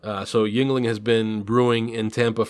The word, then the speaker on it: Yuengling: Did I say that right? I'm always a little confused. – Yingling. Yeah. I mean, it's spelled like Yuengling, but it's Yingling.